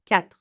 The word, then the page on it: 4: French